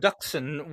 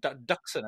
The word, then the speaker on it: Dxun: They call it Duxun when it pops up. Pops up in the second Knights of the Old Republic game, and I was very thankful to hear people saying it out loud in the game. it's spelled D-X-U-N, right? But Duxun, apparently. – In the game, they put the emphasis on the vowel that doesn't exist in the way that it's spelled, which is very, very peculiar.